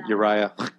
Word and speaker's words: Uriah: That's my name, Uriah. Yeah, it sounds like a loogie. Sounds like a loogie when you're doing it phonetically.